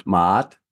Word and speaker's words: Ma'at: did i say that right ma'ut ma'at ma'at ma'at yes